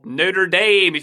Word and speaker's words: Notre Dame: Kylian was the man despite shooting his penalty over Notre Dame, or Notre Dame if you want to pronounce it all lame, but that would be a shame.